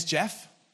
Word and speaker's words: Geoff: Uh, my name is Geoff, and I spell it with a G, which is weird.